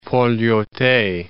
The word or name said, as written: Port Lyautey